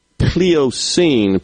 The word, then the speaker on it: Pliocene: Pliocene period. Are you familiar with that, Rhino? P-L-I-O-C-E-N-E. P-L-I-O-C-E-N-E. I guess I pronounced that right. Pliocene. – Sounds right.